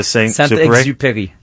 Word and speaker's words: Saint-Exupéry: Saint-Exupéry.